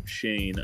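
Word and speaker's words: Schoen: Yeah, no, Brian Daboll is, and Joe Shane, I believe is how you say his last name, has been the GM, who was the Bills' assistant GM.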